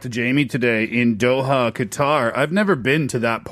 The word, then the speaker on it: Qatar: For Jamie in Doha, Qatar, as I now know is the proper pronunciation.